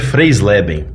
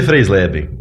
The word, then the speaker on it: Freisleben: Meu sobrenome é meio complicado mesmo, mas é descendência alemã, né? Uhum. É Peter Freisleben.